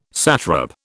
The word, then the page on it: satrap: British English